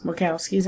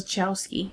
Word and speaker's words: Wachowskis: It is from the people who brought you The Matrix, the Wachowskis. – Wachowskis, how I say it. I don't know why everybody else says Chowski. It's not right.